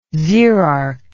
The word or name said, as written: xerarch